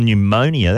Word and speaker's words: Pneumonia: Pneumonia, that's serious business. Cool. That's got a silent P on the front.